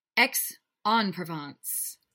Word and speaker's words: Aix-en-Provence: Aix-en-Provence.